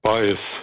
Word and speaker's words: Baez: Joan Baez oder Baez? – Baez, gell, ja. – Wieso? – Latinisch ausgesprochen. Ja.